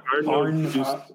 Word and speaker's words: Arnaud d'Usseau: Oh, Ar- Arnaud d'Usseau.